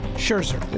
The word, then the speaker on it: Scherzer: Uh, Red Sox pitcher uh, Rick Porcello, he won the Cy Young in the American League, National League pitcher Max Cesar. How do you say his name? Cesar? Cesar? Scherzer. Yeah, of course.